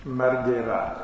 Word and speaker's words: Marguerat: Consiglierei anche un piccolo libretto di Daniel Margherat, che si scrive Marguerat,